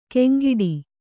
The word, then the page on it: ಕೆಂಗಿಡಿ: keŋgiḍi